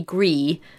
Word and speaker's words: Gris: So the other games in the category were Detroit Become Human, Red Dead Redemption 2, God of War, Spider-Man, and then Grease. – Is that how you say it? – Apparently. So if it was French, it would be Gris.